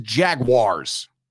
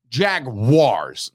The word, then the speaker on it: Jaguars: If you're watching this, listening to this, tweeting, whatever you're doing with this podcast, the pronunciation is Jaguars. Jaguars, not Jaguars.